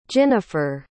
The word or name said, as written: ginnifer